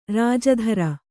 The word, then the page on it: ರಾಜಧರ: rāja dhara